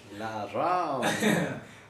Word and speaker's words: Laram: L A R A M for sure. Laram. – Got it. – Should be two A's just to make it. It sounds like the Ram.